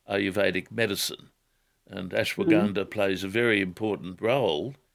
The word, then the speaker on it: Ashwagandha: You want to talk about a product called Ashwagandha. – Ashwagandha, I think. – Ganda, is it? – Yeah. I'm not sure. – Ashwagandha. – Yeah. – That's its correct pronunciation, ashwagandha.